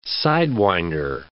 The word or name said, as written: sidewinder